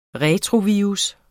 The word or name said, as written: retrovirus